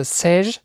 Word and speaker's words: Seiche: Man schreibt das Seiche, wird aber, glaube ich, französisch ausgesprochen, also Seiche, eine Seiche-Welle.